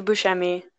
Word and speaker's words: Buscemi: Yeah, Steve Buscemi.